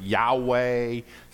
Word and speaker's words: Yahweh: In the Hebrew, so people debate how to pronounce it, Yahweh, some say Jehovah.